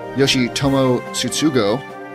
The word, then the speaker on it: Yoshitomo Tsutsugo: Japanese slugger, according to Japan at least, Yoshitomo Tsutsugo. I feel like I nailed that. – Nice pronunciation.